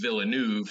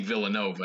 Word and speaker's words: Villeneuve: you were talking about Denis Villeneuve, the director of this movie, and pronounced his last name Villanova.